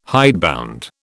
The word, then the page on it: hidebound: British English